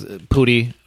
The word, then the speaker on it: Pudi: And the only reason I know that is because Pudi rhymes with cooties and cootie.